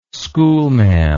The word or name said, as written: schoolmaam